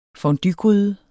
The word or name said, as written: fonduegryde